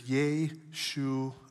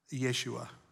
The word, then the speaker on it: Yeshua: Interesting fact that you may or may not be aware of, but the pronunciation of the Hebrew word salvation is Yeshua. Yeshua.